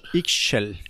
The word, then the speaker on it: Ixchel: Ixchel.